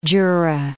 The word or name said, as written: Jura